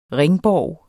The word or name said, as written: ringborg